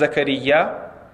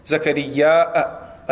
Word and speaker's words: زكريا: Some have pronounced his name as Zakaria, and others have, with a little stretch and Hamza at the end, Zakaria, a little bit of a difference in pronunciation with Mad and Qasr. Zakaria, like that, with a Hamza at the end, and just Zakaria without the Hamza.